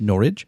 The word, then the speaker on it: Norwich: Yeah, eastern England, yes, so near Norwich, which you would probably pronounce as Norwich, but it looks like Norwich, but it's actually pronounced Norwich.